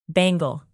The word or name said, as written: bangle